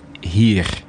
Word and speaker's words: heer: When I say H-E-E-R, it's pronounced here.